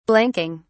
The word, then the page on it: blanking: British English